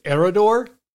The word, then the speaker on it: Eriador: One shows the Shire and one covers Eriador. Probably said that wrong. – No. You said it correctly. – Damn, Eriador.